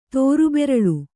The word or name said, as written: ತೋರುಬೆರಳು